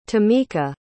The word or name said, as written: tameka